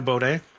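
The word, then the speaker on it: Beaudin: Hi, my name is Drew Baudet.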